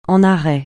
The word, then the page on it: en arrêt: francais